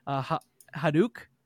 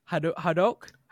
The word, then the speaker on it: Hadoque: And the developers on this one is Hadouk. Hadouk? How do you say this one? – I think that's correct. I'm pretty sure. We're going to go with that. – We're going to go with Hadouk.